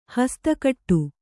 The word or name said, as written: ಹಸ್ತಕಟ್ಟು